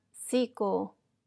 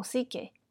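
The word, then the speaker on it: psique (ψυχή): La traducción proviene de la palabra griega que se pronuncia psico o psique, que significa alma, así como psicología, que es el estudio del el conocimiento del alma, de la vida.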